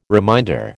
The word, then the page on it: reminder: American English